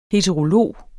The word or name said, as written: heterolog